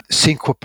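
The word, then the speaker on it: Växjösjön: Look, Mel, from a parkrun point of view, I have to say Cinque Parkrun, which, when you look at it, it's spelt Vaxjostjon with lots of little dots above it, but they assure us it's pronounced Cinque when we're in Sweden.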